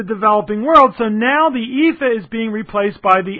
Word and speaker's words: EAFE: And the same thing is true for the EAFE, and that's how it's pronounced, don't ask me why, Europe, Australia, and the Far East.